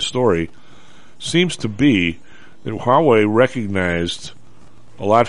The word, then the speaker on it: Huawei: Huawei? – Huawei, yep.